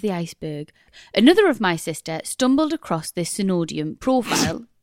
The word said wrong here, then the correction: pseudonym: Pseudonym. Pseudonym. We stopped the podcast for me to read that word, tell you what it was. I told you how to pronounce it. You said, is it Synodium? I said, no, it's pseudonym.